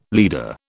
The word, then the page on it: leader: British English